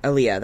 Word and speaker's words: Aaliyah: Aaliyah, that's how you say it.